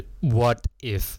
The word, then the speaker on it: what if: 那第三个呢就是 What if 如果，因为它这个通常这两个都连都是连在一起的，所以不要念成 What if，不要分开，它是 What if What if，然后中间那个 t 呢连在一起，所以它有点像是 d 的感觉 What if。